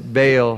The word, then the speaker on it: Baal: He's not writing about Baal, or as we pronounce it, Baal.